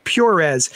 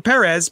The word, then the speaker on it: Perez: So I pronounce it Perez because that's how he pronounced it when he announced himself on my show.